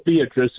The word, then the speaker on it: Beatrice: That would be spelled Beatrice in the rest of the world, but pronounced Beatrice locally.